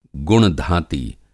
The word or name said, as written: गुण-धाती